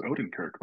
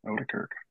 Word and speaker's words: Oedekerk: Is that how you say his name? – I actually I thought it was Odenkirk, but I think you actually probably said it right. Oedekerk.